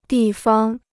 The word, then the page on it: 地方: dì fang